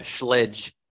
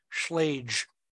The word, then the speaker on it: Schlage: I had somebody write on an email today, a government customer, call it Schledge, like a sledgehammer. – Yeah. I get Kiwi set, Schlossinger, whatever. – There was no lie. I heard another locksmith, I won't say where, another locksmith pronounced it Schlage. – Schlage. I've heard Schlage. – Yeah. – According to Bob Dix, it's Schlage because it's German, but who knows? – Real quick, how is it pronounced? Y-A-L-E? – Well, it depends where you're at.